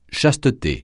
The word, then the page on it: chasteté: francais